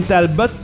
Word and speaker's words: Talbot: Et Caroline Talbot, il faut prononcer le T, nous a-t-on appris à la oui.